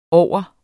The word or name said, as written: over